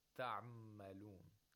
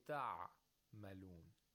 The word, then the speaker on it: تعملون: You don't say تعملون. There is no pause in between it. You say تعملون.